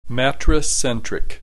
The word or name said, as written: matricentric